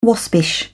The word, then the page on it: waspish: British English